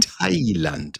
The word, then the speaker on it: Thailand: Nein, Kete, mein Lieber, bevor wir uns hier verquatschen wieder in 100.000 Sinnlosigkeiten, bitte erzähl mir 3 Sätze, wie geil es in Highland. Ich kann das auch mal normal aussprechen. In Thailand ist.